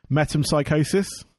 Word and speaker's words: Metempsychosis: Metempsychosis.